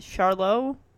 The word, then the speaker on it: Charlotte: So, her name is, let's see if I can pronounce this, Kawena, K-A-W-E-N-A, and then Charlotte, C-H-A-R-L-O-T, which I guess this T is kind of like silent, kind of like Merlot, like the wine, but Charlotte.